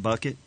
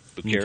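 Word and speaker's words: Bouckaert: You sure that's not Bucket? – No. – No, it's B-O-U-C-K-A-E-R-T. Bucher.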